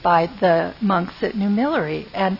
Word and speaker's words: Mellerey: M-E-L-L-E-R-E-Y. Millery.